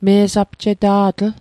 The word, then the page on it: me'sapjeta'tl: Mi'gmaq